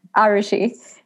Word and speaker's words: Arushi: and I know everybody calls you Arushi, but that's technically wrong, correct? – Correct. – Okay. – You're one of the few people that pronounce it correctly.